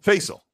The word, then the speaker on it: Facel: Facel 007 with a 449 pound super says, for each, am I saying that right? – Facel. – Thank you. Oh, that's right. I remember Facel saying it's pronounced like Hazel.